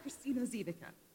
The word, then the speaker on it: Kristine Ziwicka: Kristine Ziwicka.